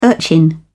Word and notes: urchin: British English

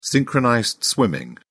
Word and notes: synchronized swimming: British English

